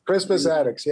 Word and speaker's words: Crispus Attucks: When I was in school, I was taught that Crispus Attuck, Crispus Attucks, yeah.